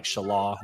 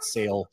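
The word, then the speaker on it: Šalé: I got to get used to pronouncing his last name like Shalaw, not Sale.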